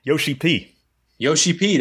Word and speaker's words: Yoshi-P: Yoshi P, that's what I thought.